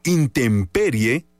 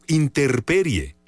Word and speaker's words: intemperie: ¿Intemperie o interperie? La forma correcta de decirlo es intemperie, con M. La palabra interperie no existe en el diccionario.